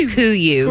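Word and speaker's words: Kuiu: It's pronounced Kuyu.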